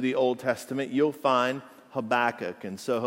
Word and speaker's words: Habakkuk: That's right, I said it right, Habakkuk.